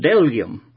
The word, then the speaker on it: bdellium: Now, that's spelled B-D-E-L-L-I-U-M, and I had to look that up because I didn't want to say it wrong, and the B is silent. It's called delium.